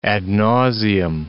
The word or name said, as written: ad nauseam